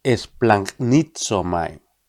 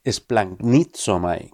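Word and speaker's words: esplagnizomai (σπλαγχνίζομαι): Los evangelios están escritos en griego antiguo, y el verbo griego que se traduce habitualmente por compadecerse, conmoverse o apiadarse es esplagnizomai. Te lo repito por si no te ha quedado claro. Esplagnizomai.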